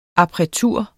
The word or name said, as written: appretur